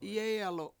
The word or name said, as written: yEEyalu7